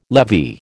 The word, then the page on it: levy: American English